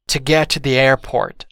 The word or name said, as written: to get to the airport